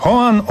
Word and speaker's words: Joan: Joan albo Joan, w zależności od tego, kto jak wymawia, Mir.